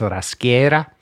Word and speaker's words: Raschera: And so he had he had Raschera, which he pronounced Raschera, and he had Castelmagno.